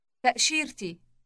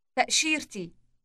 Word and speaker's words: تأشيرتي: تأشيرتي. تأشيرتي. – Note the T sound inserted before the possessive E ending. Listen again. – تأشيرتي.